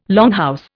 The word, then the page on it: longhouse: Indian